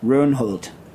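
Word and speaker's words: Rønholt: The Effective Factors on the Structure of Butter and Other Milk Fat-Based Products by Stein – I guess – how do you pronounce the O with a slash through it? Oh, right? Runholt. O with a slash through it, N-H-O-L-T, September 2013.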